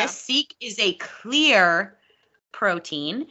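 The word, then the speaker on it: Seeq: Okay, so I'm talking today about Seek protein, but it's spelled weird because it's Seek, but it's with a Q. So it's S-E-E-Q.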